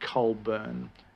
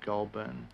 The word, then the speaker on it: Goulburn: And they should actually pronounce that Colburn as opposed to Goulburn.